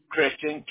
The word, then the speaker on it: Kristian: K-R-I-S-T-I-A-N? – Yes, ma'am. – Okay, beautiful. What is your email? – It's Christian, K-R-I-S-T-I-A-N, mm-hmm.